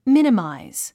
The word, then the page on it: minimize: mínimàiz; ミニマイズ